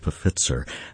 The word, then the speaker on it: Pfitzer: This is going to come to us from Hans Pfitzer. And yes, you do pronounce the P at the beginning of a PF name, believe it or not, in this case.